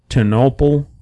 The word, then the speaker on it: Ternopil: So otherwise, we are going to Ternopil, Ukraine, I believe is how it's pronounced.